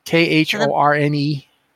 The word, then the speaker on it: Khorne: Is that Khorne? K H O R N E. – Khorne. That's how I would have pronounced it.